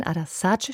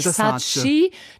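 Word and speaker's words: Ada Sarı: Müssen wir auch nochmal festhalten, Adasaci spricht sich's aus, nicht Sachi. Das ist natürlich das Typische, es wird so geschrieben und überall auch so transportiert. – Ja, es gibt zwei I-Punkte, also es gibt ein I mit Punkt, das spricht man auch so aus wie im deutschen I. Und es gibt dieses I ohne Punkt, also Ö. Und Sarge endet auf Ö.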